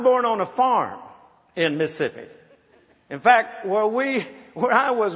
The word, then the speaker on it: Mississippi: When you're born in Mississippi, it's two syllables.